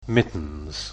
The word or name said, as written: mittens